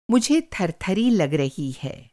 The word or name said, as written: थरथरी